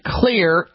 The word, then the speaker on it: Xlear: Now, you can find Clear, X-L-E-A-R, that's how you spell it.